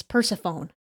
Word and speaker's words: Persephone: P E R S E P H O N E.